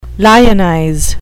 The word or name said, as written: lionize